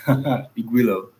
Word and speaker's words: Igwilo: Igwilo.